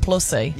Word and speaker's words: Plussie: Nah, it's but plus-y. When you put in two S's like that, it does plus-y. Become Plussy. – Yeah. – Well, why would TVNZ name their mascot Plussy? – Yeah, P-L-U-S-S-I-E.